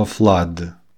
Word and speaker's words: flood: Например, слово flood – наводнение. F-l-d-o-u-d. В русском даже есть понятие flood. Это вот отсюда, кстати. – Но по-английски это читается flood.